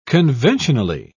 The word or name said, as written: conventionally